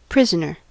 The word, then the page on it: prisoner: American English